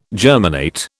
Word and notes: germinate: British English

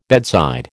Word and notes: bedside: American English

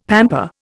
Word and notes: pamper: Indian

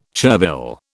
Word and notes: chervil: British English

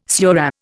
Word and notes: surah: Indian

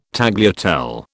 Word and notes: tagliatelle: British English

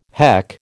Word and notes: heck: American English

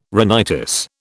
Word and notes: rhinitis: British English

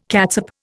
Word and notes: catsup: Indian

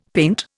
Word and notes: paint: Indian

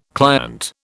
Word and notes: client: British English

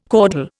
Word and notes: caudal: Indian

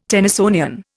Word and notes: Tennysonian: Indian

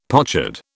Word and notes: pochard: British English

